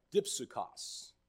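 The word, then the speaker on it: dipsukos (δίψυχος): The original word for double-minded is pronounced dipsukos, and it's a word that's made up of two parts.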